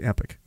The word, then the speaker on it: Epoch: This is in the Epoch Times if people want to go look for this. E-P-O-C-H. It's not Epoch. It's Epic. That's how you pronounce the word.